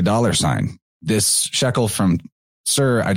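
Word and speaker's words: Tyre: I know that it looks like it says tire, but you actually pronounce that Sir. And knowing that is very helpful because you'll hear everybody in the world pronouncing it tire.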